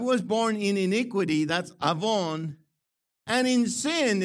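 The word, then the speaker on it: avon (עָוֹן): So the Hebrew for Psalm 51, okay, gets the word iniquity from the word avon. Not avon. Avon calling, avon, you know. The accent is on the O, avon, okay?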